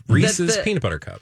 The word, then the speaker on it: Reese's: They didn't know, apparently, that the correct pronunciation of Reese's peanut butter cups is Reese's.